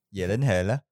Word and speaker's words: Gyllenhaal: Gyllenhaal.